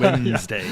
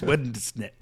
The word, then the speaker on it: Wednesday: Have you looked at the D and the N and what order they come in? It's not Wednesday. Yeah. It's Wednesday.